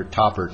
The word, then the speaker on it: Thapar: By the way, the pronunciation is Dr. Topper, T-H-A-P-A-R.